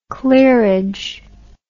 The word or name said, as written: clearage